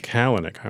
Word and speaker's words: Kalanick: Is it Kalanick? – Kalanick.